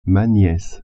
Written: niece

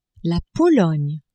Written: Pologne

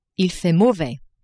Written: il fait mauvais

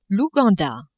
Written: Ouganda

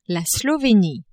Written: Slovenie

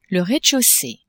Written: rez-de-chaussee

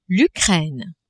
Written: Ukraine

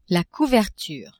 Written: couverture

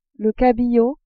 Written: cabillaud

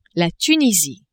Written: Tunisie

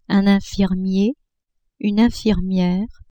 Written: infirmier-infirmiere

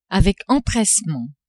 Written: avec empressement

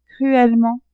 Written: cruellement

